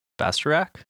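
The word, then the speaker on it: Bastarache: Bastarac? That sounds right. – That's how it's pronounced. It's kind of a weird spelling.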